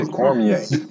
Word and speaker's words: Cormier: Y'all pronounce that wrong, by the way. Down here, we say Cormier.